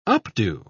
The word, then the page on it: updo: British English